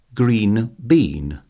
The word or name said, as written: green bean